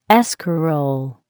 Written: escarole

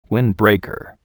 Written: windbreaker

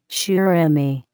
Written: surimi

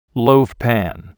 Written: loaf pan

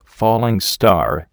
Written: falling star

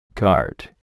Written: cart

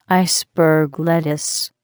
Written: iceberg lettuce

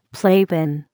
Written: playpen

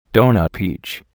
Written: donut peach

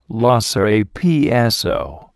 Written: Lhasa apso

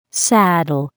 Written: saddle

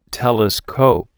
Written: telescope

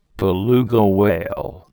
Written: beluga whale

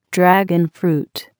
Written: dragon fruit

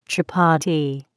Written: chapati

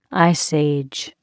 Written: Ice Age

